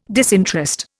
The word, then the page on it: disinterest: Indian